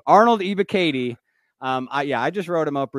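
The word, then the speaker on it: Ebiketie: I really like Arnold, and I believe I thought I was saying it right the whole offseason, but recently I was corrected. It's Ibukati, I believe. – Yeah, it's Ibukati.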